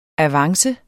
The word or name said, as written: avance